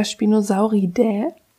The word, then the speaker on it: Spinosauridae: Spinosaurus ist der namensgebende Vertreter der Spinosauridae. Ja, so spricht man das aus.